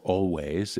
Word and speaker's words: Alvvays: Greg, I didn't get to mention always or always. Those two V's tend to confuse people. Always is how they pronounce it. A-L-V-V-A-Y-S is how they spell it.